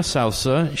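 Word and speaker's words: Souza: I was corrected back then to pronounce it Sousa, and I hope we are continuing to pronounce it right. – Somebody said Sousa, and then they were correct. They said, no, she's not related to John Philip Sousa.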